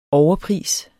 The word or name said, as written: overpris